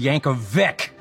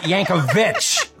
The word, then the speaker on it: Yankovic: My last name is pronounced Yankovic, not Yankovic.